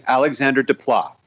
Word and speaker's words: Alexandre Desplat: Well, I could get butchered for this, but my understanding is that his name is Alexandre Desplat.